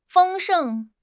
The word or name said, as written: 丰盛